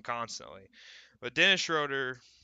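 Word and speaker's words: Schröder: I, I need to stop saying his name wrong. I, I just it freaks me out every time I say it. But SGA that, or Schroeder? – Schroeder, yeah, that guy.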